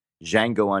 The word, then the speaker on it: Django: At number nine is Django Unchained, The D is Silent.